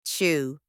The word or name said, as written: chew